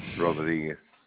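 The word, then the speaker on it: Rodrigues: J-E-S-S-E. Jesse. Rodriguez. – Okay, sir. And can you spell the last name, please? – R-O-D-R-I-G-U-E-S-D.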